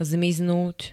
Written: zmiznúť

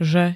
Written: -že

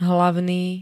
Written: hlavný

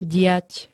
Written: diať

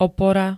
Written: opora

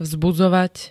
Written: vzbudzovať

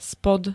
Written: spod

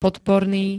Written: podporný

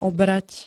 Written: obrať

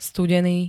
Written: studeny

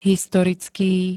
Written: historický

